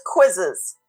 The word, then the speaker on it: Quizizz: So another one is called quizzes. This is spelled Q-U-I-Z-I-Z-Z.